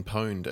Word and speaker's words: pwned: Yeah, the have I been pwned, as it's pronounced, because it's short for when you're a hacker and you take over a system, yeah. You own the system. So they nicknamed it pwned.